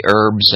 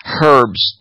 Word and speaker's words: herbs: And I always have like natural, you know, healthy lozenges that have the honey and have the herbs and all that. I love when Martha Stewart says herbs. No, it's a silent H.